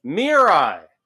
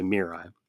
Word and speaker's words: Mirai: And since her name is in all caps, I guess I should pronounce it Mirai instead of just simply Mirai.